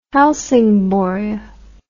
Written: helsingborg

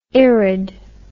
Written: irid

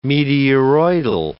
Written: meteoroidal

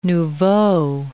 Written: nouveau

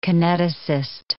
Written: kineticist

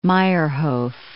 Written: Meyerhof

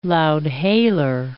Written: loud-hailer

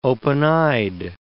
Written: open-eyed

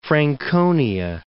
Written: Franconia

